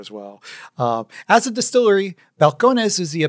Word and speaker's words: Balcones: always supposed to be pronounced Balcones. – Yeah. – And the white people decided it was Balcones. Balcones. Mayonnaise you would eat on a balcony.